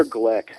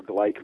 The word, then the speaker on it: Gleick: Peter Glick, his name is spelled G-L-E-I-C-K. Sounds it looks like Gleek or Gleik, but it's Glick.